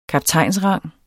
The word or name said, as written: kaptajnsrang